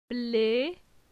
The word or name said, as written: Ble